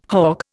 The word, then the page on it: hogg: Indian